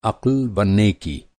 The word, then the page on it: अक़्ल: عقل